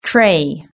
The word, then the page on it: tray: British English